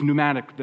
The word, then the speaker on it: pneumatic: It's where we get the words pneumatic, the P is silent, or pneumonia.